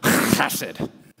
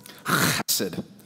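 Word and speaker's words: chesed (חסד): This word steadfast love is the Hebrew word chesed. Sorry, Stephanie, did you feel that? I'm fully vaccinated, okay? Anyway, chesed.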